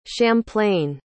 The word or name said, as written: champlain